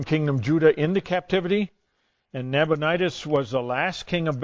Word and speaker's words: Nabonidus: Yeah, Nabonidus, yeah, that's it, yeah.